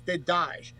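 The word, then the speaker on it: Dedaj: It's D-E-D-A-J. I think it's pronounced they die, but it's they die.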